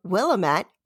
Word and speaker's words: Willamette: And um, for those of you who are not familiar with the Willamette Valley, it looks like it's spelled Willamette, but you say it like you say damn it.